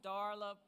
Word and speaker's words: Darla: So I'm going to make sure the first thing I do here this morning is to say my name is Darla Palmer. And you spell it D-A-R-L-A, Darla Palmer.